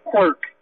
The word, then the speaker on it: Quirk: John Quirk, Q-U-I-R-K.